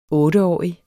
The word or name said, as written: OTTE